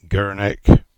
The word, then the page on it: Gurnick: English